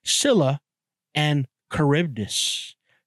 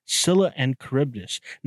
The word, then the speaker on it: Scylla and Charybdis: The next one on my list today is, I actually learned how to say this properly, Scylla and Charybdis. Scylla and Charybdis.